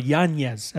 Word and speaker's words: Yanez: Or Yanez, as he wants to be pronounced now.